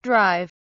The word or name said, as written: drive